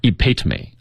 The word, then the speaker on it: epitome: 好，哎，对，我们一起来看一下啊，首先这个动词 epitomize, 它的原型是 epitome, 啊，要注意它的发音重读在第二个音节，也一定不要读错，读错了你就不知道这个词什么意思了啊。